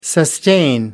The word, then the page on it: sustain: American English